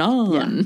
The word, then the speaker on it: Onan: And then second, Onan, am I saying his name correctly? Like Conan? Onan? – Well, from what I remember of New Testament. – Onan.